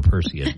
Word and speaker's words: Perseid: Oh, by the way, the other day we mispronounced it's Perseid meteor showers. – Yeah, we knew we said it wrong. We just didn't know how to. Thank you, Kevin. Send me the phonetic thing. Now I remember Perseid.